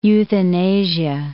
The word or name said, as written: euthanasia